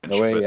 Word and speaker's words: Noé: I don't know how to say his last name. Noé. Uh, it's French. – Noé. Yeah, that's right.